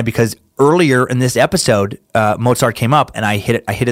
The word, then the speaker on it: Mozart: In the Einstein Time Suck, you pronounced Mozart incorrectly. I swear I'm not being picky and asking for the perfect German pronunciation. The Z is pronounced TS.